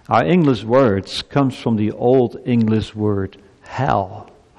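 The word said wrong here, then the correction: hāl: hall, I should say it for the good pronunciation, H-A-L, hall, which means whole. Health means whole. And interesting, the word hall, H-A-L, is also the root word for the word holy.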